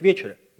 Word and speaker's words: вечере: А начну я с того, что вот мы привыкли все говорить вечере, а на самом деле правильно говорить вечере.